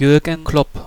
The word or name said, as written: Jürgen Klopp